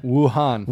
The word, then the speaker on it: Wuhan: Wuhan.